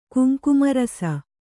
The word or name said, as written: ಕುಂಕುಮರಸ